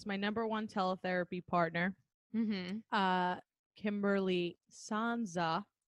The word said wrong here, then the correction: Sanzo: I pronounced her name wrong on the episode. Sanzo, excuse me, I pronounced it wrong again. Here it is. Sanzo and I both said how we use our dog as like positive reinforcement.